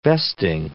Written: vesting

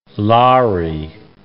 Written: lari

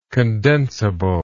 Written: condensability

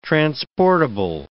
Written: transportable

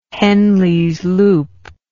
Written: henlesloop

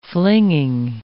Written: flinging

